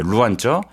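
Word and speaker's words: 루안저 (软座): 잉저라는 등급과 그다음에 루안저, 네. – 잉어, 루안어. 제가 발음이 괜찮나요? – 네, 좋아요.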